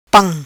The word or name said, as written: pam